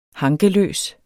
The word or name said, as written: hankeløs